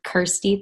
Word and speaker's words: Kirsty: My name rhymes with Thirsty, so that's how Kirsty Thirsty.